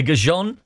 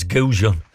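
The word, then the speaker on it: goujon: Chicken gajons? Is that how you say it? A gajon? Is that right? You know, because you're the arbiter of... No, it's not. Oh, it's gajon. Not a gajon.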